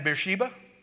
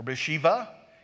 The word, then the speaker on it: Beersheba: Well, there's some linguists that think that you've heard me use this phrase from Dan to Beersheba or Beersheba, because sometimes the B's are pronounced like V's in a Semitic language.